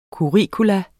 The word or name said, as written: curriculum